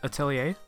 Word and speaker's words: Atelier: Are you talking about Atelier? – Yeah, Atelier. – Atelier series? – Yeah, sorry. I butchered the French language.